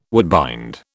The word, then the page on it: woodbind: British English